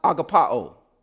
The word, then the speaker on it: agapao: Now, this word love here in the Greek is pronounced agapao. Agapao, which is where we get our word agape.